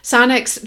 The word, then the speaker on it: Sonix: Sonics transcripts make the most of your hard work and can increase traffic to your site. Sonics is spelled S-O-N-I-X.